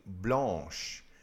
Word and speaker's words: blanche: But you know, this is one of those that changes its pronunciation a little bit when you talk about something feminine. So the feminine form would be blanche.